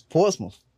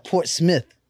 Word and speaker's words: Portsmouth: It's not Smith. It's Portsmouth. Not Port Smith.